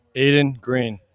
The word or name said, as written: Green